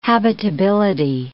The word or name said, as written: habitability